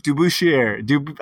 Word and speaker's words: DeBusschere: Dave DeBusschere.